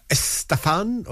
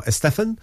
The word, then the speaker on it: Estefan: That was Gloria Estefan, or Estefan, whichever way you prefer it. I do not care.